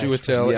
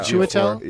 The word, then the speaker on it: Chiwetel: Okay, so the rest of the names are August Diehl, Andre Brower, Daniel Chiwetel Ejiofor. How do you pronounce that guy? – Chiwetel. – Yeah. Chiwetel.